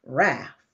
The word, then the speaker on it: Relf: So listen, please welcome to the show, Ms. Renee Relf. Renee. – How are you? – I'm doing just fine. How do you pronounce your last name? Did I pronounce it right? – It's like self, Relf.